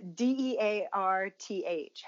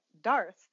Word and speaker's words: dearth: Like there was I don't know, there was something it was like uh, D E A R T H. How do you pronounce that? D D E A dearth yeah, dearth. But like every time I'd always read it, I was like Darth, and then I was like Darth, and it's like like you, it just is different.